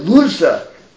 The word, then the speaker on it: Loza: And you have to know that the Munkacha, the father and son, particularly the Divrei the Divrei Loza, how do they pronounce it? The Loza. Divrei Loza. Loza. And let me pronounce it the way they do.